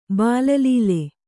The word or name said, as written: ಬಾಲಲೀಲೆ